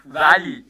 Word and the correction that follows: Wally: would have. – It's Wally. – What? – It's actually pronounced.